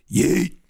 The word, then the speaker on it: yeet: She said you have to say it like, yeet.